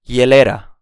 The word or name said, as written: hielera